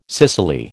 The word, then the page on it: cicely: American English